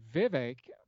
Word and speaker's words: Vivek: But Vivek rhymes with cake, rhymes with fake.